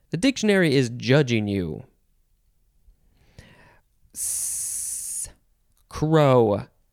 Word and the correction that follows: craw: Crow? No, craw. Yeah, it's craw. The pronunciation thing says K-R-O, but there's a dot on the O, so it means you got to pronounce it a certain way. And I just read it real quick and thought it said crow. No, it's craw.